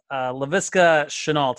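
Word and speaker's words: Laviska Shenault: Then you got Jonathan Taylor, Justin Ross, and LaVisca Chenault, if I said his name correctly.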